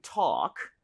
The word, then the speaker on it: talk: 자, talk라고 하는 것. L 발음은 당연히 모금이죠, 그렇죠? 오케이, 그래서 발음할 때 근데 talk라고 하지 말아주시고요. – 네. – A의 강세를 주시고 그리고 입모양을 좀 벌려서 talk보다 오히려 talk 이렇게 어에 가깝습니다. 차라리.